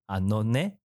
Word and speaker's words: Anone: 你现在收听的是 Easy Japan 的 Podcast 节目，这个节目的名称呢叫做编辑的 Anone。 Anone 是这样子的意思吗？是这样的发音吗？这个发音是正确的吗？ – 可以可以，Anone。